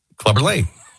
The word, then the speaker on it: Clubber Lang: I think you're saying it right. – Clubber Lane.